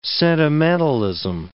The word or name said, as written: sentimentalism